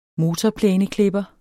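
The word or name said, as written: motorplæneklipper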